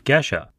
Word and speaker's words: geisha: For today's episode of Coffee Smarter, I ask Chris about geisha coffee. He very politely corrects me on the pronunciation, calling it geisha.